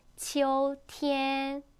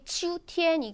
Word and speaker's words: 秋天: 발음하실 때 지우 이게 아니라 치오 오 발음 살리셔야 돼요. 치오. 그래서 가을은 치오티엔 안 이쁘게 치우티엔 이게 아니라 치오티엔이라고 표현해요.